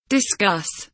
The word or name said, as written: discuss